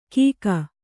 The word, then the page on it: ಕೀಕ: kannada